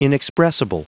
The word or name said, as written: inexpressible